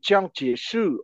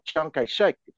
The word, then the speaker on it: Chiang Kai-shek: And Chiang Kai-shek, or Chiang Kai-shek, to use a Cantonese pronunciation of his name, was the general director for that academy.